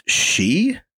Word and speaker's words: Sidhe: I think it's pronounced she, S-I-D-H-E. I don't think it's like Sidhe because it's like old Gaelic Irish.